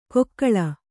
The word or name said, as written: ಕೊಕ್ಕಳ